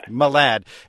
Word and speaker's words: Malad: Malad.